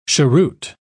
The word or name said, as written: cheroot